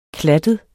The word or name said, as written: klattet